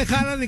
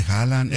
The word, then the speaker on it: Haaland: Bueno, ya que estamos hablando de Haaland y que no sé qué, el partido de...